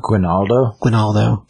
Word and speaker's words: Guinaldo: It's written by Michael Green and Mike Johnson, and the art is by Andreas Guinaldo, I think that's how you say it. Try that. – Guinaldo, that's better. As far as story,